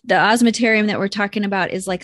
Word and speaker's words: osmeterium: So on all swallowtails also have that oscillarium, right? Is that what it's the osmeterium? – Osmeterium.